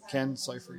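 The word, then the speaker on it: Ken Cifer: Pronounce his name C-I-F-E-R. Ken Cypher, yeah.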